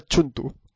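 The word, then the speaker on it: Cthulhu: pronuncia Chuntu. Quiero dejar esto claro. – También, o sea, Courtyard también es Chuntu. – Chuntu.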